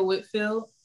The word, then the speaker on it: Whitfield: This is Akaya Whitfield.